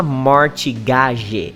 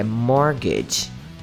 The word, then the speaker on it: mortgage: Teacher, mas cadê a pronúncia do T? Não tem. Você não precisa falar mortigage, é mortgage mortgage.